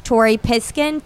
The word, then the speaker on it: Tori Piskin: Tori Piskin, T-O-R-I-P-I-S-K-I-N. – And Lulu Piskin. – Wait, spell it. – L-U-L-U.